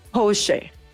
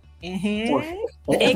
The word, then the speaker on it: Porsche: Porsche. Exactamente. – Porsche. También escuchó. – Ajá, también Porsche. – Pero a final de cuentas es como lo vamos agarrando en la tropicalización de cada país, pero la pronunciación real, por ahí me dijeron que era así, y pues ya si viene un alemán y me corrige, pues ya me pongo a su merced, porque pues él lo trata de hacer, ¿verdad? – Tú te dejas corregir por el alemán, ¿ok?